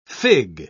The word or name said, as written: fig